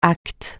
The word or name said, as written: acte